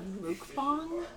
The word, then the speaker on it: mukbang: Did you just call it a mukbang? – I think mukbang is the Americanized pronunciation, but I think it's technically mukbang.